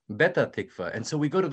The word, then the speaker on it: Petah Tikva: We want to go to Petah Tikva. But unfortunately, in Egypt, in the Egyptian Arabic, the letter P is rather difficult.